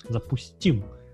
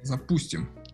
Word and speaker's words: запустим: Запустим. Запустим. Запу... Точно, вот куда ударение упасть, да. да. Не запустим, не ну, запустим.